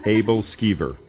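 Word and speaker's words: æbleskiver: Abel Skeever, yes. Now, my wife would probably pronounce it a little differently than I would, but that's the American translation.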